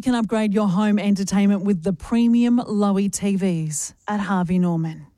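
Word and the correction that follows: Loewe: Lover. – Oh, is it Lover? – See, at the top how it says Lover. – Oh, yes. – Almost rhymes with sofa.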